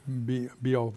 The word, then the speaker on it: Beowulf: Beowulf.